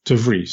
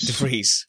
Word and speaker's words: De Vries: De Vries. – De Vries.